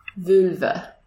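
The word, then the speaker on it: völva: Vulva, V-O with umlauts, L-V-A, means seeress.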